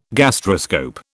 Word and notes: gastroscope: British English

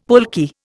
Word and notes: pulque: Indian

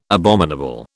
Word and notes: abominable: British English

